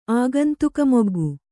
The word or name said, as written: ಆಗಂತುಕಮೊಗ್ಗು